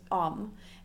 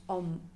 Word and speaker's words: om: So in the Western world, often rather than pronouncing it as om, it's pronounced om, om, to rhyme with home.